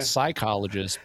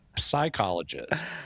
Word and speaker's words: psychologist: Well, you do it all the time at the end of the word lips. – Oh, yeah. – So all you got to do is transfer that same sound at the beginning of the word. And it's not psychologist, but psychologist.